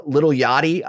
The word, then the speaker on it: Lil Yachty: And dude, I was like, okay, this is going to be corny and stupid, but it's with Wiz Khalifa, Lil Yachty.